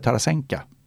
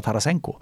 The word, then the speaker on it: Tarasenko: Och då uttalade jag Tarasenka med A på slutet, för O blir ju A. Liksom. Det känner man ju till i den ryska uttalsguiden. Liksom. Så jag sa Tarasenka. Det blev ett himla liv, för att väldigt många tittare hade ju relation till NHL och där säger ju alla Tarasenko.